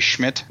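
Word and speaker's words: Schmit: Flickr, you can find me there as CJ Schmidt.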